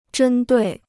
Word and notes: 针对: zhēn duì

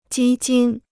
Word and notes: 基金: jī jīn

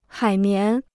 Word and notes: 海绵: hǎi mián

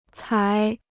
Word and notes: 采: cài

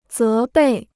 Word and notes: 责备: zé bèi

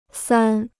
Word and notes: 三: sān

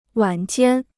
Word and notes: 晚间: wǎn jiān

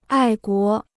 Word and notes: 爱国: ài guó

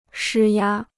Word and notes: 施压: shī yā

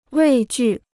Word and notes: 畏惧: wèi jù

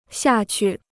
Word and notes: 下去: xià qù

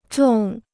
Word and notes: 种: zhòng

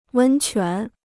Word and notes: 温泉: wēn quán

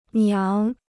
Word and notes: 娘: niáng

